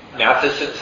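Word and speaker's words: Matthiessen: I'm reading a wonderful novel by, if I can say his name right, Peter Mathiesen, guy who wrote... Matheson's how you say it.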